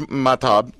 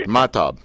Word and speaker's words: Mahtob: My name is Mahtob. You see, I put the right emphasis on the first part. Mahtob, right? – Yes, perfect.